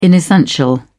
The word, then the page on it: inessential: British English